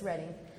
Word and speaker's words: Reading: actually it's pronounced Reading. We would look at it as Reading, but it's Reading, for a short time there.